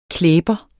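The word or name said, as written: klæber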